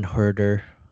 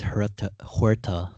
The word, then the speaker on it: Huerter: I mean, Trae Young, John Collins, DeAndre Hunter, Kevin Huerter. I thought it was Huerta the whole time. I don't know why. There's an ER at the end, and I mispronounce names a lot.